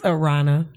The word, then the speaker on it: Aarona: It's Aarona.